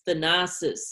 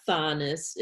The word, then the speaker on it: Thanasis: We watch Bucks games together, and I'm constantly being like, no, it's Thanasis, it's not Thanis, it doesn't rhyme with Giannis.